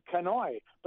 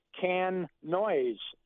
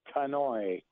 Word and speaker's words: Canoise: So then the group members decided we're going to call our band Kanoi. But the way it's spelled, you would think it would be can noise, C-A-N-O-I-S-E, but its pronunciation is Kanoi.